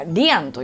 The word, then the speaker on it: lien: リルエンというのは、まずはリアンという LIEN と書いてリアンと読むんですけれども、これフランス語でつながり、絆っていうのを意味してます。